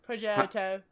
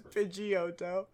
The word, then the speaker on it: Pidgeotto: Pidgeotto. – How do you say it here in the West? – In the West, we say it as Pidgeotto.